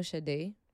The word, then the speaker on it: Sade: Do you know Sade? – No.